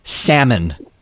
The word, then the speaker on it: salmon: Salmon. Drives me up the walls. It's salmon.